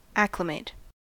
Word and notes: acclimate: American English

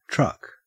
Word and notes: truck: Australian English

